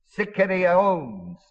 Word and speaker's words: sikarion: This word, murderers, is the word sikarion, spelled S-I-K, A-R-I, long O-N. The sicarii, which is plural, S-I-C-A-R-I-I, were so bold and so strong and so adamant that they deliberately murdered people in daylight.